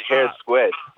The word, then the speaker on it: Hare Squead: Hare Squead.